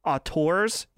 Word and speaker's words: auteur: And just to see his his rise as we were talking about a few few weeks ago, the art uh, uh, I always say it wrong auteurs, yes, yeah, the auteur, yeah.